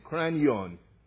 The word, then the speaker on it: kranion: Well, the Greek word for this place is spelled K-R-A-N-I-O-N, Cranion.